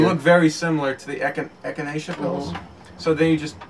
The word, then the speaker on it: echinacea: Echina. – Is that how you pronounce Echina. – It? Echina. – Wait, it's an echinacea.